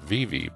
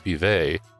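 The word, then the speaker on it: Vevay: Actually, it's V-E-V-A-Y, and I think the locals around here pronounce it Vevey, but I would say it's Vevey, but what do I know?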